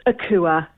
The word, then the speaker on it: Akua: It's Akua.